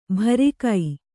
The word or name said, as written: ಭರಿಕೈ